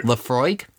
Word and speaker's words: Laphroaig: Go for it. – Laphroaig. – Ooh, nicely done. You are learning things.